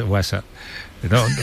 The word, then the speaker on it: WhatsApp: WhatsApp. No, peor aún, con acento en la primera.